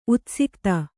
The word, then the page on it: ಉತ್ಸಿಕ್ತ: utsikta